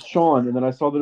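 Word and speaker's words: Siân: It's pronounced like the English S-E-A-N, yeah.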